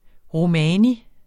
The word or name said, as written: romani